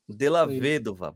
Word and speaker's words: Della Vedova: Cal Della Vedova. – Della Vedova, boa.